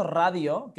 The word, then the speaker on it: Radio: La primera es que he visto que has españolizado un poco la pronunciación de Radio Bite Size. – Que has dicho, ¿sí? – Has dicho radio, que es muy español, ¿no? – Claro, pero yo siempre digo radio bite.